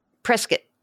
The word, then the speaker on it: Prescott: Prescott, like biscuit, Mark says.